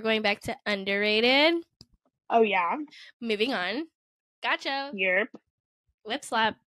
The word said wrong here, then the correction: whiplash: Is that how you say it? Whip slap? – Whiplash. Whiplash. Whiplash.